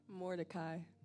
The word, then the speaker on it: Mordecai: Mordecai. How do you say it? Thank you. Say it again. Yeah, American. What? – Mordecai. – Mordecai. Did I say that right? Mordecai. Okay. I have a cousin called Mordecai. That's how you say it.